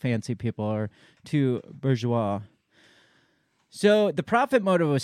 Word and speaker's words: bourgeois: Bourgeois. – What's that? Bourgeois.